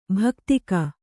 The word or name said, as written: ಭಾಕ್ತಿಕ